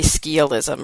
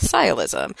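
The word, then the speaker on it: sciolism: It doesn't, it again doesn't sound how it looks like it should sound, because it looks like it should be skialism or skialism, because it's SC, but it's pronounced sialism. – Like skyance. – Yes, like skyance, and they have the same root.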